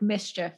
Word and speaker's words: MSCHF: And so now the collective, which is called MSCHF, like mischief, I'm sure is how that's pronounced, they are not allowed to fulfill any more orders.